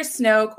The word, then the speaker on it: Snoke: Is that how you say his name? Snoke? – Yeah.